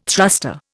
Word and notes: thruster: Indian

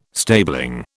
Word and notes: stabling: British English

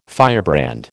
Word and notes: firebrand: American English

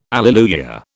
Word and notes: alleluia: British English

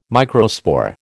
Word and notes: microspore: American English

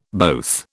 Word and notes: both: British English

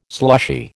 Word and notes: slushy: American English

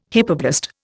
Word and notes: hypoblast: Indian

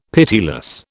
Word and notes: pitiless: British English

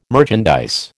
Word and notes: merchandise: American English

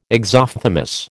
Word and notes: exophthalmos: American English

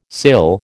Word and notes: cill: American English

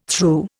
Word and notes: thru: Indian

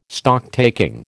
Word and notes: Stocktaking: American English

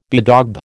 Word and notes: bedaub: American English

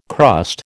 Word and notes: crust: American English

